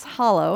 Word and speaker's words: Hallow: So tell us a little bit about what is this Hallow, and I think I'm saying it right. Like hallowed be thy name, right? – That is perfect.